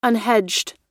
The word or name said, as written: unhedged